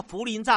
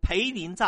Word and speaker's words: 涪陵: 可是他自称财经专家，他在电视节目里面，他公开说了，大陆人很穷，吃不起杂菜，所以呢，涪陵榨菜应该叫涪陵榨菜，他念错，中文太差，他就念成培林榨菜，也闹了笑话。